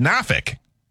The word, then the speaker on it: Norfolk: And we'd say Norfolk, but everyone down there says Norfolk. – Norfolk.